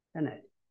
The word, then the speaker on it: Pilate: who in his testimony before Pontius Pilate, how do you pronounce it? Pilate, all right.